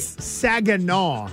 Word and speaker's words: Saginaw: Saginaw.